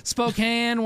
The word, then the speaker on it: Spokane: Spokane. Why don't they spell it S-P-O-C-A-N-E? Spokane, Washington.